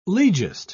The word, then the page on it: legist: British English